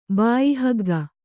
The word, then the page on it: ಬಾಯಿಹಗ್ಗ: kannada